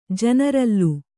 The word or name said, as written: ಜನರಲ್ಲು